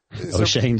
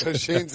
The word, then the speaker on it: Oshane: How do you say it again? – O'Shane, just like that. Just don't even.